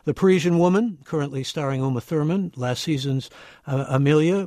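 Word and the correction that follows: Amélie: which is, did I say that right? – Amelie. – Amelie. Okay, thank you.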